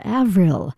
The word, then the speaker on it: Avril: Not Samuel Coleridge Taylor, but his daughter Avril, spelled like April but with a V.